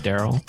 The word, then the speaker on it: Daryl: How do you say your name, Daryl? – Just like that, Daryl. – Daryl. He puts a little more A into it. – It works. – We're just straight D-U-R-L.